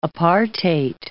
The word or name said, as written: apartheid